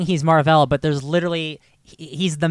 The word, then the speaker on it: Mar-Vell: Just Mar-Vell. – Yeah. – Sure. – Like, if you say it like it's two names.